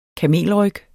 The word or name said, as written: kamelryg